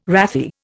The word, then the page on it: wrathy: Indian